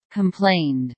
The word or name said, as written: complained